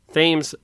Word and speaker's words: Thames: Now, Thames, I looked up, this is the proper pronunciation according to Google. It's spelled T-H-A-M-E-S, and hopefully it's not actually pronounced Thames, but it's Thames.